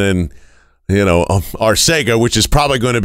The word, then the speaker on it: Arcega: JJ is totally fine if you say Arcega, but he clearly told us Arcega.